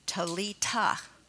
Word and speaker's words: Talitha: I looked up the word it looks like Talitha Kumi, but it's Talita.